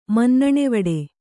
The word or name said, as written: ಮನ್ನಣೆವಡೆ